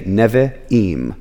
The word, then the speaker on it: Nevi'im: So for this word, when you've got two I's next to each other, you would say Nevi'im. It would not be Nevi'im or anything like that.